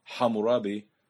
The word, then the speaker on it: Hammurabi: Hammurabi, or as properly pronounced, Hammurabi, by more than 300 years.